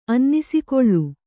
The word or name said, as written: ಅನ್ನಿಸಿಕೊಳ್ಳು